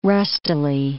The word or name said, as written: rustily